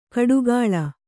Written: ಕಡುಗಾಳ

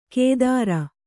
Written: ಕೇದಾರ

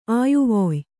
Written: ಅಯ್ವಾಯ್

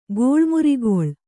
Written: ಗೋಳ್ಮುರಿಗೊಳ್

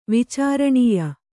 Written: ವಿಚಾರಣೀಯ

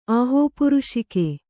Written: ಆಹೋಪುರುಷಿಕೆ

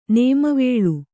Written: ನೇಮವೇಳು

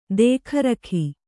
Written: ದೇಖರಖಿ

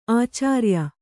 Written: ಆಚಾರಿಯ

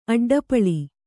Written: ಅಡ್ಡಪಳಿ

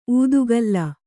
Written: ಉತ್ಕಲಿ

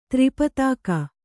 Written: ತ್ರಿಪತಾಕ

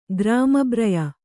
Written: ಗ್ರಾಮಬ್ರಯ

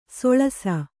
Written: ಸೊಳಸ

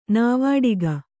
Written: ನಾವಾಡಿಗ

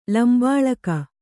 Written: ಲಂಬಳಿಗೆ

